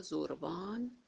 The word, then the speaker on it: زربان: در اینجا شاهرخ مسکوب توضیحی داره در مورد زربانیت که توضیح میده که زربانیت منصوب به زروان یا زربان به معنی زمان در اساطیر ایرانی و آیین زرتشتی هست که نام ایزد یا فرشته زمان است. هم زروان هم زربان، فرقی نمی‌کنه، تلفظش هر دو درست.